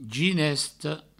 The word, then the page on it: ginesta: ginesto